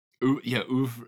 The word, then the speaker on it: oeuvre: oeuvre? – Oeuvre.